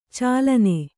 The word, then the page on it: ಚಾಲನೆ: cālane